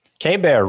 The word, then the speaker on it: Keibert: K-Bear Ruiz, yeah. – Keibert Ruiz. – I think so. – I stink. What? Nah, nothing. – It's K-Bear.